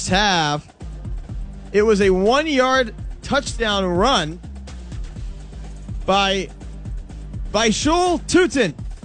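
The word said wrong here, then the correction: Bhayshul Tuten: Basil Tootin, I believe, is the correct pronunciation.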